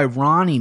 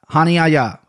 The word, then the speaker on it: Hani Yaya: I think he wants to go by Ronnie now because Brazilian guys are trying to go with the American pronunciation. But, I mean, his name's Hani Yaya. Hani Yaya, who is 26-10.